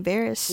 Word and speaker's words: Varys: Varys? – Yeah, that's his name.